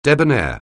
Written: debonair

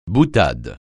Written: boutade

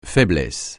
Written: faiblesse

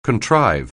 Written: contrive

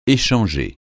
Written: échanger

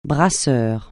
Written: brasseur

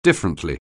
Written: differently

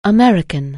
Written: American

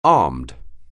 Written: armed

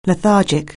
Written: lethargic

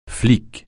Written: flic

